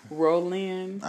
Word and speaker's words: Rolynne: R-O-L-Y-N-N-E. – That sound like Rolyn. Roy. Rolyn. – Rolyn. – Rolyn. – Uh-huh. – Is that, well, you said R-O-L-Y-N-N-E. – Mm-hmm. – That's definitely Rolyn. – Okay. – Or Rolyn, if you want to put it all together.